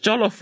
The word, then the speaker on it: jollof: It's just jollof rice. Jollof rice.